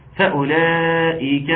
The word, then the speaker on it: فأولئك: مفيش حرف الواو في كلمة فأولئك. فأولئك اسمع مني وشوف هنطق واو ولا لا. فأولئك ده الضمة بتاعة الهمزة، فأولئك يبقى رسم فقط.